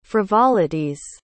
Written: frivolities

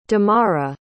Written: damara

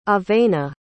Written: avena

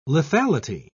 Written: lethality

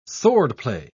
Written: swordplay

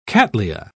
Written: cattleya

